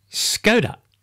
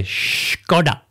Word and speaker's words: Škoda: The reason they did this was because Škoda is often mispronounced as Skoda. – Did I say it right before? – What did you say? I can't remember. Well, I'm not saying it again. – I don't think you did. You're supposed to say Škoda.